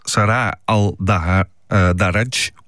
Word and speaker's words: Sarah Al-Daraj: Ja, sorry voor de gebrekkige uitspraak, maar het is niet een alledaagse naam, zullen we maar zeggen. – Het is Sarah Al-Daraj.